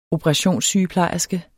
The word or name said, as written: operationssygeplejerske